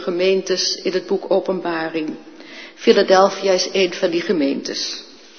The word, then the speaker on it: Philadelphia: Ik kijk naar Manfred, doe ik nou de klemtoon goed? Ja, ik doe de klemtoon goed. Philadelphia.